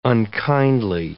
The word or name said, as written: unkindly